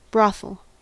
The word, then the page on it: brothel: American English